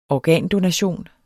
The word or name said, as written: organdonation